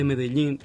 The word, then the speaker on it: Medellín: Depende, o duplo L se pronuncia de formas diferentes através da América Latina e da Espanha, né? Então, sim. na Espanha é mais Medellín. – A Espanha é Medellín, sim.